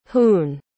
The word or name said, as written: hoon